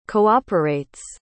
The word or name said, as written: cooperates